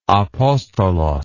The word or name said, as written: apostolos